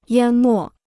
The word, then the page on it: 淹没: yān mò